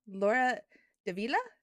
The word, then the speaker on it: Laura Davila: Today I am joined by a very awesome author, and she is the author of Mexican Sorcery, Laura Davila.